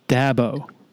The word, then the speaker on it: Dabo: The correct pronunciation is Dabo.